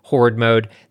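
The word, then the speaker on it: Whored Mode: Another example, there was a mode in Saints Row the Third called, I'm sorry for this, it's called Horde Mode, but it's spelled W-H-O-R-E-D, Horde Mode.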